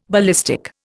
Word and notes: ballistic: Indian

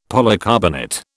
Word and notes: polycarbonate: British English

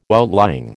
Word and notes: outlying: American English

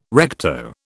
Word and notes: recto: British English

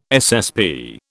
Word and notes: SSP: British English

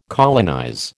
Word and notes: colonize: American English

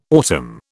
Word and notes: autumn: British English

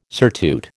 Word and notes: surtout: American English

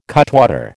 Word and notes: cutwater: American English

